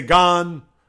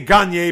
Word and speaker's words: Gagne: I know people are going to say Gan. People are going to say Gain when you look at it and you're going to read it the way it's spelled. But I'm going to say Gagne because obviously he's from France.